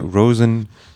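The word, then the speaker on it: Róisín: Nee, wird R, dann ein O mit Apostroph, I-S, I mit Apostroph, I-N, aus Irisch, Roisin, geschrieben. – Oh, zu viele Apostrophe. Hat aber, glaube ich, ja. Rosen ausgesprochen, Rosen Murphy, in Kollaboration mit DJ Kotze.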